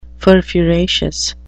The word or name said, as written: furfuraceous